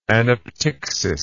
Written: anaptyctical

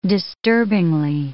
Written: disturbingly

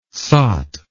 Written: sot

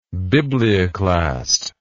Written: biblioclast

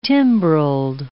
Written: timbrelled

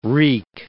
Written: reek

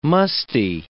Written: musty

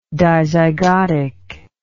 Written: dizygosity

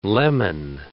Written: leman

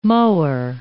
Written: mohur

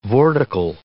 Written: vortical

